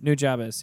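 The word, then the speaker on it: Nujabes: I don't know how to pronounce it. – New Jabez, yes.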